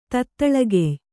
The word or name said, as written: ತತ್ತಳಗೆಯ್